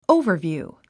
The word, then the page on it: overview: óuvə r vjùː; オゥヴァビュー